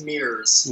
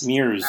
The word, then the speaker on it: MIRS: That's part of the, how do you pronounce, M-I-R-S? Is that pronounced, or is that supposed to be an act? – It's pronounced Mears. – Mears.